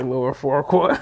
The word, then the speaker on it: Farquaad: He said Lord Farquaad. – How you say it? – That's it.